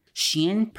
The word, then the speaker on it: Shein: Shein, pronounced Shein,